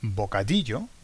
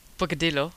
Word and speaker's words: bocadillo: If you say bocadillo, I don't know, it sounds like vodka hielo. Vodka and ice. – That's right, yeah. Okay, so pronunciation is very, very, very important. So where are we now? We're the double L and the Y has the same sound, which is like a J.